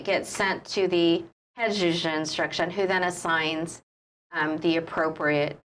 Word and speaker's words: juge d'instruction: It gets to a complaint, the juge d'instruction, did I say that right? – Mm-hmm.